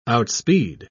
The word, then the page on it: outspeed: British English